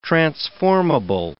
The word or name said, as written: transformable